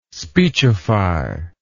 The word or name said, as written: speechify